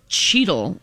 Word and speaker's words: Cheetle: But it is Cheetle. Like Cheetos produce Cheetle on your fingers. – It's spelled like Don Cheadle spells his last name.